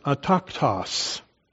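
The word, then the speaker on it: ataktos (ἄτακτος): It's the only place in the New Testament that this word occurs, pronounced ataktos.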